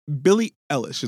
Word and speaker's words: Billie Eilish: And so, Billie Eilish, is that how you pronounce her name? – Yes.